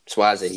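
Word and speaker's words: Sauzée: Frank Sozzi. – Swazzy, was it? – Swazzy, you're right. Been saying his name wrong the whole time.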